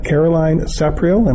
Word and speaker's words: Caroline Sapriel: And I'd like to introduce to the show Caroline Sapriel. Am I saying the name right? – Correct.